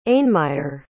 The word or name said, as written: ainmire